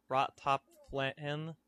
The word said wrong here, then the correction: Rot Lop Fan: Rot Lop Fan.